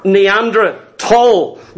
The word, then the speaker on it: Neanderthal: First of all, the Neanderthal, or Neanderthal, because it's a silent H being a German word.